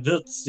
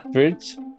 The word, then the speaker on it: Wirtz: Ah, yeah, yeah, Florian Wirtz, yeah, yeah. – Wirtz, yeah.